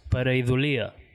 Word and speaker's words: Pareidolia: Vale, a veure, una pareidolia és... en castellà és pareidolia. Pareidolia, ah, crec que... Però ho vam demanar a un... erudit lingüista amic nostre que mos va dir que ho havíem de pronunciar pareidolia, ¿vale?